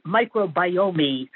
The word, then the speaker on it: microbiome: If he listens to public radio, he will hear multiple other shows pronouncing the word correctly. Microbiome. Biome is something that doesn't exist. It's the microbiome. That last E is silent.